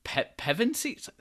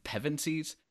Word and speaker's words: Pevensies: I just wish that this story was flipped, where it was actually about Prince Caspian, and then the Pevensies? Is that how you pronounce their name? Pevensies? – Yeah, which I don't believe actually got their last name yet. But yes, I think so.